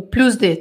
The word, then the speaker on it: plus de: A primeira vez eu pronunciei errado. Agora estou pronunciando certo. O plus de, tá? E plus de.